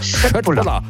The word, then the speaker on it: Köttbullar: Oder wenn wir es ganz korrekt aussprechen, ich habe mir sagen lassen, das K wird in Schweden wie ein Sch ausgesprochen. Also wenn es Köttbullar, hat es doch geklappt.